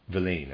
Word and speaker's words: Villaine: He was the clerical senator Gaudin de Villene, and I'm going to forgive my pronunciation, Gaudin, G-A-U-D-A-I-N, and then D-E, and then V-I-L-L-A-I-N-E, Villene, I'm going to say Villene,